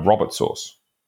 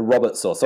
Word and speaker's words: Robert: What did you call it? – A Robert sauce. – A Robert sauce.